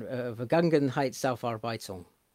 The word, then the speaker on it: Vergangenheitsaufarbeitung: Vergangenheitsaufarbeitung. Is that right? – Pretty good attempt, yeah.